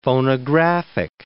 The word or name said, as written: phonographic